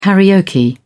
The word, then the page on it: karaoke: British English